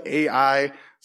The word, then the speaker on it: Ai: It's spelled A-I. Some people pronounce it A-I, but Ai is the city.